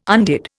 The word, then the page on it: undid: Indian